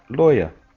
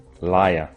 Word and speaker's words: lawyer: The general term for abogado or abogada in English is lawyer. The pronunciation is or. Lawyer, not I, liar. There's not usually a connection between lawyer and liar, so be careful of your pronunciation.